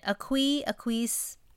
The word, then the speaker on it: Aquis: I don't know, ever know how to pronounce it. – Aquis.